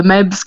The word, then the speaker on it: Meadhbh: No, the Meadhbh comes from just the way my name is spelled. It's the Irish way, the consonants. So it's M-E-A-D-H-B-H.